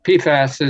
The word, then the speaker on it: PFAS: As you heard in that song and in the intro, Tom pronounced it PFAS, or however you say it. – PFAS is usually how it's called.